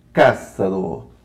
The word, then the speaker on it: Ksaro: Com o querido Bruno Cássaro. Agora eu acertei a pronúncia italiana.